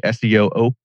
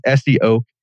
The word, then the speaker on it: SEOak: because people say SEOOK and all this other stuff, and you know, like, oh man, I didn't think about that. But yeah, SEOOK, and the oak tree